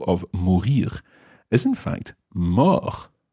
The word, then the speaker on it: mort: It's spelt M-O-R-T. Now, in English, we have words like mortuary, the place where dead people are kept, in a sense. So, mort, M-O-R-T, linked to other words that start with M-O-R-T.